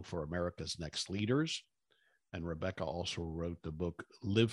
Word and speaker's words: Rebecca: And Rebecca is spelled R-E-B-E-C-C-A.